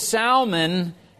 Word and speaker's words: Salmon: The Bible says, "And Salmon," I have to say it like that because otherwise it sounds like a fish. "And Salmon begat Booz of Rahab, and Booz begat Obed of Ruth, and Obed begat Jesse."